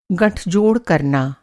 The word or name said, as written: गँठजोड़